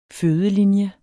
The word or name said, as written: fødelinje